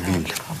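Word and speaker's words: ville: Потому что «виль», например, произносится как «виль», несмотря на то, что там дублирующийся «виль» является исключением.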